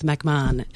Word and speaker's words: McMahon: I can pretend that I like the surname McMahon. No, I don't. My name is Beth McMahon, and I want a long vowel sound.